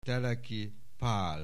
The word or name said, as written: Teleki Pál